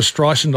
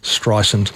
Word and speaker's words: Streisand: Really, remakes of that same movie, and they played that that female role, Judy Garland, Lady Gaga, and Barbara Streisand. So you, those of you who are pedants know I'm pronouncing Streisand correctly now. It's not Streisand.